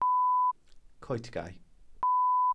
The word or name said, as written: coetgae